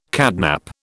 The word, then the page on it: catnap: British English